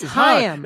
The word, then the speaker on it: Haim: Like, is it Haim or Haim? – It's Haim. – It's Haim, and that's H-A-I-M.